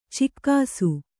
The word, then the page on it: ಚೆಕ್ಕಿಸು: cekkisu